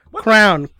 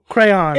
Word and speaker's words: crayon: Am I saying it right now? Crayon? – No. – Crown. – No! – Crayon. – It's not C-R-W-N.